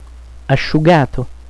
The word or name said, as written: asciugato